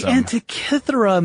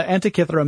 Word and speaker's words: Antikythera: Yeah, yeah, it all depends on which pronunciation you follow. But Antikythera seems to be fairly commonplace, so we're going to go ahead and use that one and probably switch off without even thinking about it.